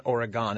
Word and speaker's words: Oregon: Did I pronounce Oregon right? – Yeah, it's not Oregon. It's Oregon.